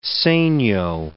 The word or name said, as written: segno